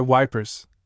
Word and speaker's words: Ypres: I made a serious blunder when I asked one of them a question about Ypres, for I pronounced the name French fashion, which put me under suspicion as a swanker. "Don't try to come at sun," he said. "S.Y. Wipers, that's what we calls it."